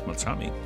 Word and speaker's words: Beltrami: Yes, Beltrami. – Is that how you pronounce it? – Yeah, well, because clearly I'm Italian, so I'm qualified.